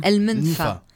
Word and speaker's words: El Menfa: Tu sais mettre l'accent tonique là où il faut. El Menfa, c'est ça ? – El Menfa.